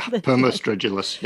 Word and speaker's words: Permostridulus: But then you describe, I think, this like cricket-like creature, Permostridulus. Am I saying that right? – Yeah, Permostridulus, yes.